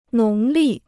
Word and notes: 农历: nóng lì

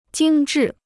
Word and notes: 精致: jīng zhì

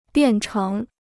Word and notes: 变成: biàn chéng

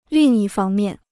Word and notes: 另一方面: lìng yī fāng miàn

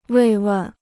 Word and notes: 慰问: wèi wèn

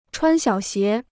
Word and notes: 穿小鞋: chuān xiǎo xié

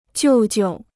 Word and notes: 舅舅: jiù jiu

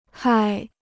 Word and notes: 害: hài

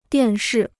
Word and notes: 电视: diàn shì

